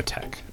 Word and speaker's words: TeX: Oh, Tech. – Yeah. – Wait, is that how you pronounce T-E-X? – Yeah. – I think it's not. – is like a K sound.